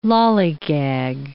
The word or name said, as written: lollygag